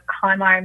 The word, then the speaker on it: KonMari: But what changed for me is what we'll talk about later, which is the Konmari method of organizing.